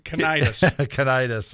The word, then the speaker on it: Cnidus: Cnidus. – There you go. That's close. – I thought it was a silent C.